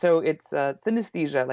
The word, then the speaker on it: synesthesia: Kingdom, there's actually an A in the middle of the word, so you mispronounce it like synesthesia. – Yeah. – But it's synesthesia, like anesthesia, but synesthesia.